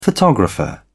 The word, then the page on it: photographer: British English